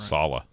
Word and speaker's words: Saleh: I've always been impressed with Saleh. I think I'm saying his name right. – Saleh. Robert Saleh.